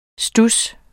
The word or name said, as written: studs